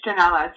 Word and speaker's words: Janela: You're close. Yeah, no, it's Janela. So, and for those who don't know, you know, Janela is actually the Portuguese word for window.